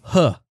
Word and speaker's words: He: And his name is Huh.